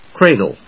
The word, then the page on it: cradle: cra・dle; kréɪdl